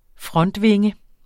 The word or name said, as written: frontvinge